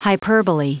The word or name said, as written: hyperbolae